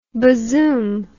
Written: bazoom

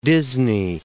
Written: Disney

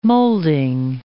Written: moulding

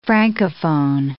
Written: francophone

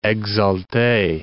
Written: exalte

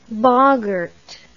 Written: boggart

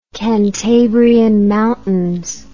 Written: Cantabrian Mountains

